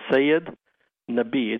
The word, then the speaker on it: Syed Nabi: It's a different pronunciation and which part of the country you are in. It's Syed Nabi. It's actually an Urdu word, but the Americanized version is Syed. And maybe sometimes Nabi or Nabi.